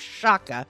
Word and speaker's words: Shakir: Yagdir Shaka, which is spelled S-H-A-K-I-R, but it's pronounced Shaka. – Sounds like chakra.